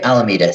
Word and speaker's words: Alameda: Alameda.